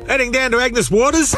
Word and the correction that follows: Agnes Water: Mate, it's pronounced Agnes Water.